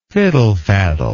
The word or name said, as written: fiddlefaddler